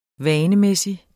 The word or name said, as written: vanemæssig